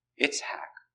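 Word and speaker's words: Itzhak (יצחק): Itzhak is the pronunciation in the Hebrew language.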